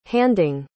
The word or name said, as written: handing